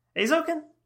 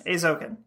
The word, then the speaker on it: Eizouken: Eizouken? – Yes. – Eizouken. Yeah, Eizouken. – Thank you, Ben from Tasmania, on the correction. – Yeah, I've been saying it wrong for a year.